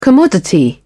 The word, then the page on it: commodity: British English